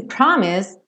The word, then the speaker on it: promise: Esse promise também o E não é pronunciado. Esse S tem um leve som puxadinho para o Z, mas você não precisa se preocupar, pode direto.